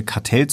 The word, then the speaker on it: Catel: sehr erfolgreichen deutschen Maler Franz Ludwig Kartell oder Kartell, wie man es immer auch aussprechen möchte. Er war Hugenotte oder er kommt aus einer hugenottischen Familie, deswegen erlaube ich mir Kartell zu sagen.